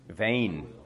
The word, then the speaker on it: vain: Vain. Excellent. Tam bacha na tu výslovnost, tam je opravdu v. Jako ne u, ale v. Takže vain.